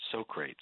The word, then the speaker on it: Socrates: And by the way, you mispronounced it. It's Socrates. Make a note of that.